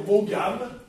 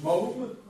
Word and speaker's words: Maugham: Puisqu'il ne faut pas dire Maugham, ça se prononce Maugham.